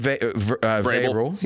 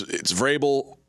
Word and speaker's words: Vrabel: We gave out Tennessee and Vrabel. – Yeah, that V, it always confuses me about how silent that is because people seem to say it half. It's almost like a half pronunciation.